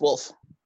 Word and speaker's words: Woolf: Because it's spelled W-O-O-L-F. – O-O-L-F? There's two O's? – Yeah. – I thought there were two F's. – No. – Huh. Yeah. Yeah, it's still pronounced Wolf.